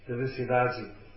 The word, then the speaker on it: Felicidade: In Buenos, no, in Rio de Janeiro, and they say Felicidade. They pronounce D-A-D as with a like that Dadi, it's Dadi, Ciudadi.